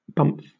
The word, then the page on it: bumf: British English